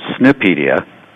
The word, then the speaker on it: SNPedia: Well, it's actually pronounced Snipedia, which is, you know, a riff on Wikipedia,